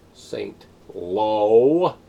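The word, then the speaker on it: Saint-Lô: Saint Low. It's pronounced low, not suck it.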